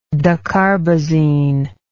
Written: dacarbazine

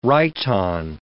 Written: rhyton

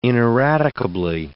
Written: ineradicably

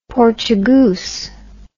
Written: Portugoose

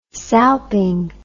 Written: salping

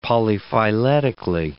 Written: polyphyletically

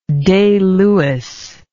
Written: Day Lewis

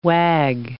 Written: wag

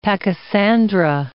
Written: pachysandra

